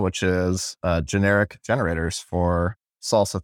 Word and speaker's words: SLSA: So SLSA, and you pronounce it salsa.